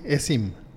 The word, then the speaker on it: eSIM: Fíjate que fui a una telefónica X porque quería un ESHIP. ¿Sí se dice así? ESIM un ESIM, un ESIM.